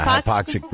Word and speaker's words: hypoxic: And he encourages doing that with what's called hypoxic breathing. Am I saying that right? – Yeah, hypoxic breathing.